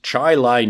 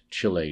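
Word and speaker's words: Chili: So it is Chi-Lai, New York, that Lincoln went to, passed through, and not Chile, New York, as I had said.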